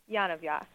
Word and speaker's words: Yonavjak: Yonavjak.